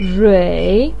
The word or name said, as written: rui3